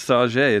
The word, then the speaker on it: Sauget: Is it really Sauget? – It's Sauget. It's a French pronunciation.